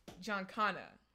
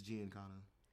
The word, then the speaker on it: Giancana: he goes, uh, how do you say this? What is it? – John Connor. – Yeah, or is it Giancana? – John Connor or Giancana? – It's Giancana.